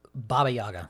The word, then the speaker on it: Baba Yaga: The Papa Shot. How do you say it? – Baba Yaga. – The Baba Yaga.